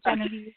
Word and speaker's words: Genevieve: Yeah, no, and before we get going, just the pronunciation of my name is actually Genevieve. You had it the second time. It's a tricky one to say, so I go by Genevieve.